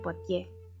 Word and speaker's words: Poitiers: Desde este terminal debía tomar el tren hacia Poitiers, que se pronuncia Poitiers, sin mover mucho los labios y listo.